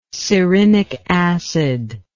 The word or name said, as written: cerinic acid